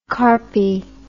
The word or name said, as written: carpy